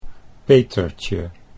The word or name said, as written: Petertje